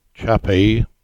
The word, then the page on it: Chappie: English